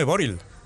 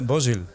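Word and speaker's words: Bořil: El, el día este del Slavia de Praga, el lateral este que marcó y tal, este hombre en la, camiseta pone Boril, pero se pronuncia Bosil. Con, con S. Una R con dos puntitos arriba. – Es, S, no es, R. No es Boril, es Bosil.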